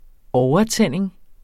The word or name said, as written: overtænding